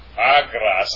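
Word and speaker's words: Agras (ἀγρός): Ha argas. Agras, I'm sorry. Ha agras. Agras, actually.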